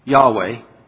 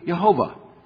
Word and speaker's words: Yahweh: The Lord, Yahweh, the name of God first used in Genesis, and it's pronounced, we think, Yahweh. They think that's most likely Yahweh. Or it could be Jehovah.